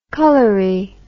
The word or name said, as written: colory